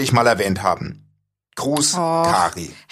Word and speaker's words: Kary: K-A-R-Y, wie würdest du den Namen aussprechen? Carrie oder Carrie? – Carrie.